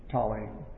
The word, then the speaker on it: Ptolemy: spelled with a silent P at the beginning, P-T, Ptolemy, P-T-O-L-E-M-Y, Ptolemy.